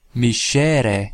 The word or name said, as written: miscere